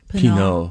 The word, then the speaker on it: Pinault: Francois Henri, H-E-N-R-I, Pinault. How would you pronounce that in French? – Pinault.